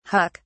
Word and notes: huck: British English

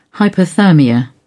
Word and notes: hypothermia: British English

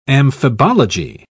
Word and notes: amphibology: British English

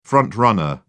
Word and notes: front-runner: British English

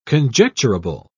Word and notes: conjecturable: British English

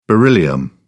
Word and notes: beryllium: British English

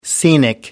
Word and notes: scenic: American English

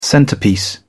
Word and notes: centerpiece: British English

